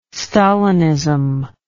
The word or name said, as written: stalinism